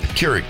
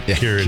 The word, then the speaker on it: Keurig: Johnny brought in a coffee maker because Krieg. – Krieg. – Keurig. – Keurig. Keurig. Yeah. You don't even know how to say it.